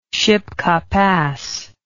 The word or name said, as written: shipka pass